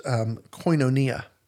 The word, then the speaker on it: koinonia: It's pronounced koinonia.